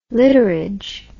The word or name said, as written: literage